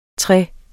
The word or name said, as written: tre